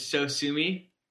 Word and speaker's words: Sosumi: They had one that was spelled S-O-S-U-M-I, which phonetically is so sue me.